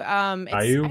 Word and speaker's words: Caillou: So they were, I guess one group was watching Kowloo. Is that how you say it? Kowloo? – Caillou? – I remember it. Is it Caillou? Okay, Caillou. – Caillou or whatever? – Yes, yeah, that's Caillou. Caillou.